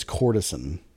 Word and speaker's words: courtesan: I don't think it's Cortesian. I think it's courtesan. – Is it cortisone? – Cortisone. Cortisone shot. – I thought it was a Cortesian. Let's look up the pronunciation. – How do you spell it? Let me see. That's not the right group chat. It's definitely courtesan. C-O-U-R-T-E-S-A-N.